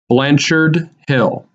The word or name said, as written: Blanchard Hill